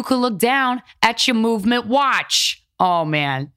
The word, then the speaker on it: MVMT: It's pronounced Movement, but it's spelled MVMT.